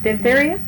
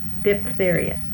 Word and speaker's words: diphtheria: Diphtheria? Diphtheria? – Okay. – Now, I often say diphtheria, too, but I, generally speaking, would probably pronounce the PH as an F.